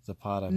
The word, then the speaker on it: Zapata: Zapata.